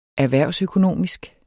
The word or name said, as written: erhvervsøkonomisk